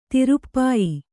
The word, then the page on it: ತಿರುಪ್ಪಾಯಿ: Kannada